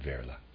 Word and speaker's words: Wehrle: It's either Verla or Whirl. W-E-H-R-L-E. So I'm going to pronounce that Verla.